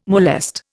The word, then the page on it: molest: Indian